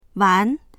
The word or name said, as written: wan2